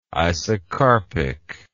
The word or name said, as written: isocarpic